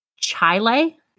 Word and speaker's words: Chili: Now, this town is spelt like chili, like the soup, or like the chili, like the stew. I don't know, whatever chili is. New York. But it's actually pronounced Chile, C-H-A-I-L-A-I, based off of my Google search.